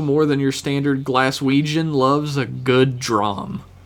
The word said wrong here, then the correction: dram: Oh, is that a dram? – Dram. I'm sure it's pronounced dram.